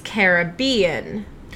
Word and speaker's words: Caribbean: So the correct pronunciation, it is of the West Indies, is Caribbean. So Caribbean in the Caribbean, right? So Pirates of the Caribbean is the right way to say it because that word comes from an indigenous tribe called the Caribs.